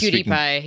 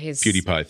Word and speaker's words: PewDiePie: Felix PewDiePie, never know how to pronounce that guy's name, basically said that he was PewDiePie. – PewDiePie, thank you very much.